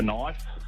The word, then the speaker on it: knife: The old silent K. – Knife. Yeah. I'd spell it with an N, but no one else does. – Yeah, nah, the silent K.